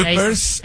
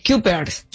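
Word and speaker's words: Cuypers: A ver, Kuipers, así se escribe, C-U-Y. – Kuipers.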